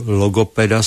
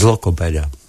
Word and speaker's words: logopeda: Bylo to bez lokopeda. Logopeda.